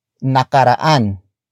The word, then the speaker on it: nakaraan: And that is nakaraan. Nakaraan. Nakaraan. Nakaraan is spelled as N-A-K-A-R-A-A-N.